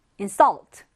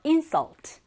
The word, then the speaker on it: insult: 24번째 단어, insult or insult. 명전 동무가 정확하게 적용이 되는 단어입니다. 발음상으로 insult가 되면 명사로 뭘까? 모욕이라는 뜻이고요. insult라고 하면 모욕하다, 즉 뭐야?